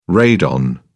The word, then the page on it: radon: British English